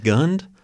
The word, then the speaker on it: Gund: Anyway, 1994, the Cleveland Cavaliers' first game at Gund Arena. – Yep. – Am I saying that right? Gund? – Yes.